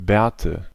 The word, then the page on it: Bärte: /ˈbɛːɐ̯tə/